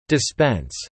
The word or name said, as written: dispense